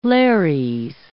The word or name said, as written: Lares